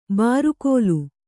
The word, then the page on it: ಬಾರುಗೋಲು: bārugōlu